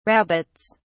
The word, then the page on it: rabbits: British English